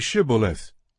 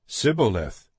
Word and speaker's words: Shibboleth: When he said, "No," they said to him, "Then say Shibboleth." And he said, "Sibboleth," for he could not pronounce it right.